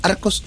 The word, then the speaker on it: Archos: Fíjense después, cuando tengan tiempo, de mirar el Arcos 5. Se escribe Archos 5.